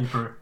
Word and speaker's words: Ypres: Ypres.